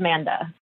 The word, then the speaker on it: Manda: It's actually just Manda. – Oh, just Manda. – Oh, the first A is silent.